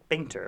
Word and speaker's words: Bainter: Also has Faye Bainter. Is that how you say her name? Bainter, of course.